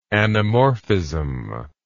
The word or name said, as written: anamorphism